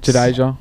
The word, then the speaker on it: Jadeja: as a spinner for For Jajada. um, and Jadeja. – There yeah, go. Yeah, sorry, that was that was right pronunciation.